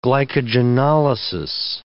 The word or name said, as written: glycogenolysis